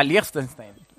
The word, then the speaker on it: Liechtenstein: A Liechtenstein.